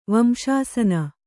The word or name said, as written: ವಂಶಾಸನ